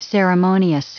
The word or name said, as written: ceremonious